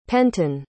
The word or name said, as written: penton